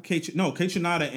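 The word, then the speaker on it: Kaytranada: I always say it, K-Tronome. You know how to say it, right? – K-Tronada? – K-Tronada and Anime. – Anime. – I always do that.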